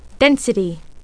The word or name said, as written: density